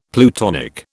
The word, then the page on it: plutonic: British English